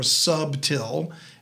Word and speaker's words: subtil: So now the serpent was more subtil. That's how it's pronounced in a King James Bible.